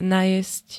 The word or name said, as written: najesť